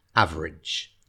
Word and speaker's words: average: And in terms of pronunciation, average, yeah, spelled A-V-E-R-A-G-E, average. Okay, so we sort of run the last two syllables together, average. Good.